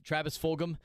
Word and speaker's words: Fulgham: Great throw to Travis Fulgham. Am I saying his name right, Jack? Travis Fulgham. – Correct.